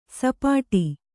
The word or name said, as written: ಸಪಾಟಿ